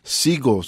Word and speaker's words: Sego: es como nace este proyecto que se llama Sigo, se escribe Sego, y sacan en el 2016 su álbum debut, Once Was Lost, Now Just Hanging Around.